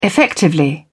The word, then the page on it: effectively: British English